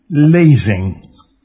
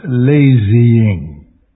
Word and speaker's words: lazing: Observe, the pronunciation is lazing, not lazying.